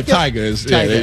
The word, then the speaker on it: Tyga: Am I saying that right? – Tyga? Yeah, Tyga. You're pronouncing that right.